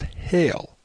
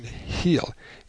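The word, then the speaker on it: hele: In Inman's emulation working explained, it is stated that the word is pronounced hail. But I think, notwithstanding this, that the pronunciation heel is the correct one.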